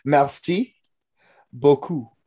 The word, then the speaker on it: merci beaucoup: Merci doo doo. Did I get it? No. Merci beaucoup. Beaucoup.